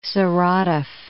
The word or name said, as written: Saratov